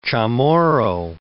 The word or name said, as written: Chamorro